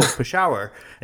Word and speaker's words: Peshawar: And I'm like, "No, Peshawar." And he looks at me and he goes, "How do you know that?"